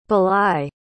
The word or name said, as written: bely